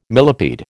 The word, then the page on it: millipede: American English